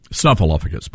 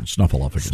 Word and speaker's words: Snuffleupagus: Snuffleupagus, by the way. That's the correct pronunciation. Snuffleupagus.